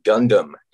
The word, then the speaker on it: Gundam: which is from the Gundam. Am I saying that right? – Yeah.